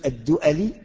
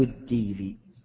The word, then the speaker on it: الدؤلي: أبو الأسود الدؤلي أو الديلي، من يعرف اسمه؟ أبو الأسود اسمه الديلي أو الدؤلي، تنطق هكذا وكذا، من يعرف اسمه؟